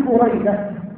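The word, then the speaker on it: بُرَيدة: بريدة، هكذا ينطق بفتح ضم أوله وفتح ثانيه،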